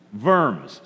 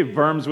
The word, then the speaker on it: Worms: Worms. Say Worms with me. Worms. It's spelled worms, but you pronounce it Worms, and it's always fun to pronounce fun words.